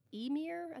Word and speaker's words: Ymir: When that happened, and the ice started to melt, the heat, the droplets, became a creature called Ymir, I think that's how. It's Y-M-I-R, Ymir, I think, because I, you know. Went to YouTube and asked how to pronounce it.